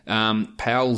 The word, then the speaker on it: Paul: Paul Zipser, who I criticized the announcers for calling him Paul, but apparently in German he is pronounced Paul.